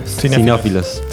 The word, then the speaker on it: cinéfilos: cineófilos creo que se dice así, no sé, cineastas. Cinéfilos. – Cinéfilos.